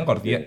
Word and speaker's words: gordiano: lleva al nudo jordiano, ¿lo he dicho bien? Gordiano.